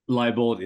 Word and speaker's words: Liebold: Is it Liebold? – Liebold is exactly right.